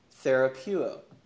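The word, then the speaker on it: therapeuo (θεραπεύω): In fact, the word that he uses here is a Greek word pronounced therapeuo. Now, if you know your English and you start thinking about words that sound like that, you're probably hearing some English words here. Therapeuo, therapy, therapeutics, right?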